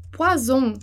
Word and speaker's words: poison: E eu dei o exemplo nas aulas anteriores, do poisson, né, que é o peixe, e do poison, que é o veneno. Então, ficar bem ligado nessa pronúncia dos dois S e do um S, porque muda o som, muda o sentido da palavra, né?